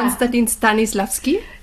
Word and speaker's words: Konstantin Stanislavski: Bin ich da jetzt richtig? – Ein richtiger Name. Das ist ein schwieriger Name. Ist da ja auch ein Künstlername. – Richtig ausgesprochen ist es ja. Konstantin Stanislavski. – Ja, ist sehr schön ausgesprochen.